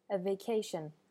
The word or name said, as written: a vacation